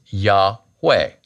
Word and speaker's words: Yahweh: A-O-I, the vowels for Adonai, over the consonants Yod, He, Vav, He. Now, we think that the correct pronunciation for the Tetragrammaton, God's name, Yod, He, Vav, He, or YHWH in English, is Yahweh.